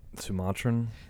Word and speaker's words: Sumatran: I need your help with this pronunciation. Sumatran. Sumatran. – Sumatran.